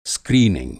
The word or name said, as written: स्क्रीनिंग